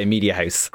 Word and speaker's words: Mediahuis: Who is group head of news at Mediahuis, which of course, how do you pronounce media? Is it Hoosh? – House? – What do you go with? – Well, on Talbot Street, we say Mediahuis.